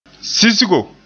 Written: sisgo

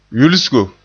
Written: yulsigo

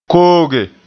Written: kooge

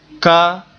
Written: ka

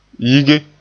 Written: yige

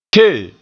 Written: ke

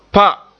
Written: pa

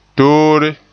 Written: toore

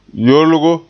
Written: yolgo

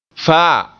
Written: faa

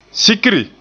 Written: sikri

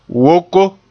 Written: woko

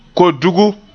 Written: kodugu

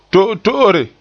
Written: to'oto'ore

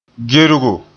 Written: gergo

